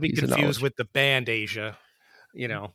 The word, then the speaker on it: Aja: So it's spelled AJA. So if you never knew how to pronounce it, like Aja, or it's Asia, it's Asia. – Like the continent.